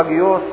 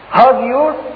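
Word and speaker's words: هاجيوس (ἅγιος): وكلمة قديس من الكلمة اليونانية هاديوس، اللي بتسمعه ابونا بينطقها كتير اجيوس اجيوس اجيوس. هاجيوس اللي هي اخدنها دلوقتي وبيسموها كلمة حج.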